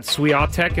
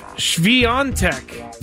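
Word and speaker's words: Świątek: Okay, so I got a note that Swiatek is not how you pronounce her name. – Who'd you get the note from? – I don't remember, but they were nice about it. Swiatek is how you do it.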